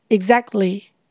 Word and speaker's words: exactly: professional 专业的四个音节，professional，professional exactly。没错，exactly 原本是指完全地，这边是表示完全认同对方的意思。在发音上，x 要念成 gz，gz，t 可以念成 stop t，也就是把气流阻断，暂时停顿，不需要念出 t 的声音。